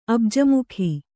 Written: ಅಬ್ಜಮುಖಿ

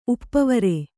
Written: ಉಪವರ

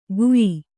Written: ಗುಯಿ

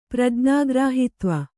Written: ಪ್ರಜ್ಞಾಗ್ರಾಹಿತ್ವ

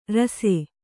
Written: ರುಸಿ

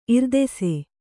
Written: ಇರ್ದೆಸೆ

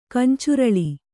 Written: ಕಂಚುರಳಿ

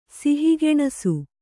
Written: ಸಿಹಿಗೆಣಸು

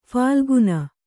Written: ಫಾಲ್ಗುನ

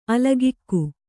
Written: ಅಲೌಕಿಕ

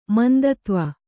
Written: ಮಂದತ್ವ

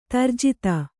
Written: ದುರ್ಜಾತ

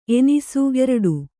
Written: ಎಣ್ಣಿಸು

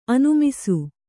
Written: ಅನಿಮಿಸ

